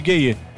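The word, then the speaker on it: Gueye: Por sinal, os gayê da seleção de Senegal, na real, a pronúncia correta é gay. É o Papi gay. Sim. E o Gana gay. Mas mundialmente eles viraram os gayê.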